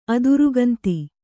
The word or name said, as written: ಅದಿರುಗಂತಿ